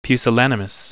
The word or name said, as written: pusillanimous